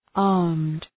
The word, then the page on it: armed: ɑ:rmd